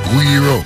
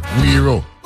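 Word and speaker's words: Güiro: Pronounce it Guiro. You're right. Yeah, the word is of Latin American origin, and the U and I blend together to make it a crisp we. You're right, Guiro.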